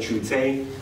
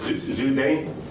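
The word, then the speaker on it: Zhu De: so in the English we used to say Chute, now it's Zude.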